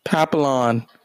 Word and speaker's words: Papillon: Papillon.